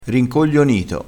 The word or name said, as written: rincoglionito